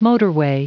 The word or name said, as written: motorway